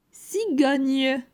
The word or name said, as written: Cigogne